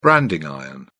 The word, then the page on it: branding iron: British English